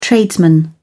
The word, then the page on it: tradesman: British English